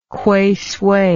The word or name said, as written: Kweisui